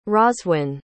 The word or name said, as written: rhoswen